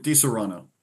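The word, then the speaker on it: Disaronno: I did get Di Serrano, or is that how you say that? Or how you butcher it? – Di Serrano.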